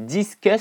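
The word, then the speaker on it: Disqus: Et puis comme ça je vais pouvoir le présenter à donc ce plugin s'appelle Discus, ça s'écrit D-I-S-Q-U-S. D-I-S-Q-U-S.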